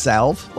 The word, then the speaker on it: salve: And by the way, isn't it pronounced salve? Like, why do you put an L in a word and not use it?